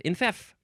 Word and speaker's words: enfeoff: Next word is infef or infief. Infef, infief. And the first syllable can be in or n. It's spelled E-N-F-E-O-F-F. Watch out for that O. E-N-F-E-O-F-F. Infef.